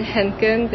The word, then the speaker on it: Henken: So we have Chris Pearson and we have Ben Henken.